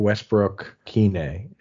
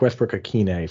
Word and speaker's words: Westbrook-Ikhine: Nick Westbrook Kine is Nick Westbrook-Ikhine.